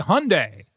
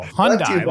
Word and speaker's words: Hyundai: They've sponsored our baseball and football games, and I'm like, brought to you by Hyundai. No, Hyundai.